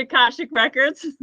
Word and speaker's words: Akashic Records: The Akashic Records.